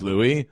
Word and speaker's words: Louie: Or L-O-U-I-E. How do you say it? I think it's Louis. – Louis. – Correct.